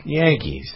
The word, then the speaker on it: Yankees: Yankees. – It rhymes with hanky.